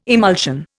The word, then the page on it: emulsion: Indian